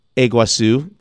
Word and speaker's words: Iguazu: I can't never pronounce that. Falls Day something. Iguazu. Thank you.